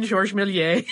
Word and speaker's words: Georges Méliès: I did have to laugh, though, when someone had emailed us and asked why we did not pronounce the R in Georges Méliès' name, but we did pronounce the R in the Lumière brothers' name.